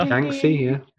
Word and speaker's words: Yangtze: Yangtze, yeah.